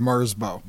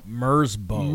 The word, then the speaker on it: Merzbow: The song is by Merzbow. Am I saying that correctly? – I think so. I'm okay, pretty sure it's Mersbo. – Mersbo. – Mersbo.